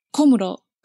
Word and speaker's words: Komuro: And there was specific criticism of the fiancé of Kamuro. Am I saying that incorrectly? Is it Kamuro or Kamuro? – Komuro. – Komuro. Thank you.